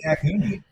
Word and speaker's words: Raccacoonie: Am I saying that right? Rakakuni? – Rakakuni, yes.